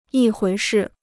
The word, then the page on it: 一回事: yī huí shì